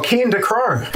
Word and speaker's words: Cian Ducrot: We went to go watch his concert, and I'm so sure I'm pronouncing it wrong, but it's Cian Ducrot. I've butchered it. It's an Irish name. – Oh, Cian Ducrot.